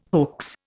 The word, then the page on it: hoax: Indian